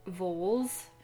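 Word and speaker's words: Vholes: The whole novel starts off with one, and we're getting one right now with Voles, which his name is spelled V-Holes, and I can't get out of my head somehow.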